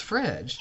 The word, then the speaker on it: fridge: Initially, fridge was spelled like frig, which is what you automatically think it would be pronounced that way. But no, it was fridge.